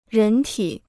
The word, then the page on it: 人体: rén tǐ